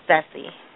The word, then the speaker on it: Sessy: Sessy. – Ooh, spell that. – S-E-S-S-Y. – S-E-S-S-Y.